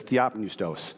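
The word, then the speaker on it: theopneustos (θεόπνευστος): The word is theopneustos. And I have pronunciations for how to speak these because I don't speak Greek, but it's theopneustos.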